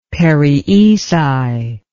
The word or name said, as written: perioeci